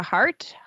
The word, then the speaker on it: Hart: And my name is Jessica Hart. Hart is spelled H-A-R-T.